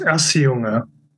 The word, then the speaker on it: Auseuga: It's pronounced Auseuga.